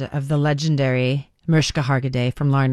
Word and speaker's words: Mariska: I mean, she said, by the way, she likes her name, even though it's spelt with an I, she refers to herself as Mariska. – Mariska.